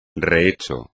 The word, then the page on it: rehecho: espagnol